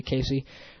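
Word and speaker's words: KC: For all of you out there that can't pronounce names, my name is not Kazi Soul. It's not Kazi Soul or anything like that. It's K-C Soul. It's not that hard to pronounce it. I think it looks like that. When I look at it, I immediately say Casey.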